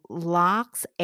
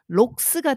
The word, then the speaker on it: lox: 근데 저는 어그 하이스쿨 때 이락 락스 A 사운드가 나는 것 같으면 LAX라고 쓰고 싶었거든요. 근데 스펠링이 맨날 LOX 그러니까 록스 같은데 영어권에서는 LOX로 쓰고 락스라고 읽으니까 어이제2 외국어를 하는 저에겐 너무너무 큰운동을 줬었거든요.